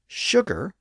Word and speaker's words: sugar: That's why the word sugar, spelled S-U-G-A-R, is pronounced with an initial sh sound today. And that's also why the word sure, S-U-R-E, is pronounced in a similar way with a sh sound at the front.